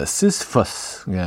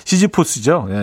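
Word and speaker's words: Sisyphus: 앤줄 버디의 음, 시스, 시스퍼스, 예, 시스퍼스 예, 영어 발음을 그렇게 해주죠. 시지포스죠.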